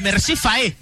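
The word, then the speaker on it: Emerse Faé: Y el caso de Mercé Faé, ¿lo dije bien el técnico, Fede? – Exactamente, bien pronunciado.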